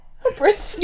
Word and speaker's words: hubris: It's two syllables. – Hubris. – You're saying it like humorous, but like hubris.